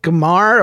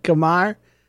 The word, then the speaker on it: Khmer: Yeah, I'm not even fluent in English, much less much less Khmer or whatever you said. Khmer. – I think that's how it's pronounced. Khmer.